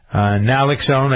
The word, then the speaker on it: Naloxone: Advil, they make opioid drug overdose reversal, Naxalone. Am I saying that right? Naloxone, I guess it is. N-A-L-O-X-O-N-E.